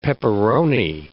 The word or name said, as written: pepperoni